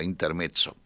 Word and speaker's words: Intermezzo: Intermezzo con doble, yo lo pronuncio a la italiana con doble Z, Intermezzo.